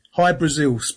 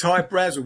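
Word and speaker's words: Hy-Brasil: High Brazil, people pronounce it High Brazil, and it's Brazil, okay. – Yeah, yeah. But it's High Brazel because that's how the Irish would pronounce it, because it's it's an island that's off the southwest coast of Ireland.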